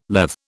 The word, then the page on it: Lethe: British English